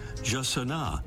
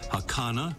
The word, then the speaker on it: jacana: About those pronunciations, the Brazilian Portuguese way is Jacana. Spanish versions include Hacana and Hassana and Hassana. There may be others, but I think we'll quit there.